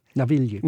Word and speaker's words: Navigli: And how do you say this district, the canal district? – Navigli. – Navigli. – Navigli.